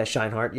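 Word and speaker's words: Scheinert: I think it's Scheinhardt. – Yeah, Scheinhardt. Yeah, you actually said it correctly, way better than I did.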